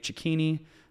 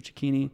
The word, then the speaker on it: Cecchini: Link will probably be in the description, but it's just my first and last name, Dave Cecchini. That's how you pronounce it. It's like zucchini, but chick, Dave Cecchini.